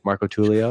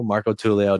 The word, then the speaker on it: Marcotullio: Marcotullio? – Marcotullio, there you go. – Oh, Marcotullio.